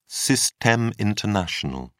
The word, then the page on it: systeme international: British English